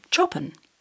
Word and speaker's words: Chopin: Two of them were brothers, William and Alfred Chopin, spelt the same way as the famous composer, though they pronounced it Chopin.